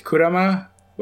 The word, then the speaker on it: Kurama: the way I've been pronouncing it and Yu Yu Hakusho pronounced it was Kurama. Apparently, the proper pronunciation is Kurama, which I'm probably not going to use.